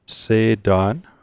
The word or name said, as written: Se'ta'n